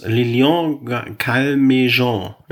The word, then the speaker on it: Calmejean: Äh, wie spricht man den aus? Lilian Calmejean. Ja.